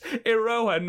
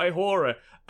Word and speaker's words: Iroha: So, Velky John on Discord said, oh, lovely podcast episode, but it's Iroha, not Ihora.